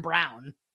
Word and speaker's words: Braun: Wow, it's kind of stupid that his name is spelled like Ryan Braun, but it's pronounced like Christian Brown.